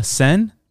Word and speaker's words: Seine: The Seine. – Seine? – The Seine. – I don't, it sounds like you're. – The Seine. – The Seine? – The Seine. – The Seine? – Seine. – How you spell that? – S-E-I-N-E.